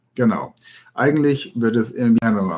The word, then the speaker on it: Invierno: Ja, also das, das W, das wird wie Invierno, wie ein B eigentlich, Invierno ausgesprochen. Das B wie ein B, bei dem die Lippen sich nicht berühren, zum Beispiel Trabajar, Trabajar, Invierno, Trabajar.